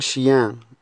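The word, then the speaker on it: chien: ان شيان. شيان يعني كلب، لان الاخيرة تنطق من الانف.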